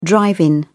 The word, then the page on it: drive-in: British English